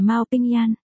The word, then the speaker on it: Mao Bình Nhạn: Mao Phiến phát âm là Mao Pin, Mao Bình Nhạn phát âm là Mao Ping Yan.